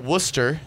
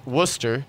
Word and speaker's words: Worcester: Once we're kind of going over the sports that happened this past week, but the volleyball team was at Worcester this past weekend Worcester. Sorry, I always say that wrong. I'm an Indiana boy. They were at Worcester. Did I say that right now? – Yes. – Worcester.